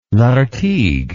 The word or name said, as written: Lartigue